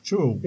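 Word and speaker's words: Zhou: Man skulle uttala det som brittiska Joe, kom jag på här nu. – Han har berättat för mig. – Joe.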